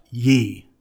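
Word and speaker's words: ye (γῆ): And the word translated earth, it's in the Greek, it's ge, pronounced ye, offers no specificity.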